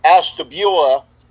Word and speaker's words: Ashtabula: By the way, I asked Jared Bunch about being from Ohio, and I found out the correct way to say his hometown is Ashtabula, because I asked him about going to Michigan, and that was a great story the other night.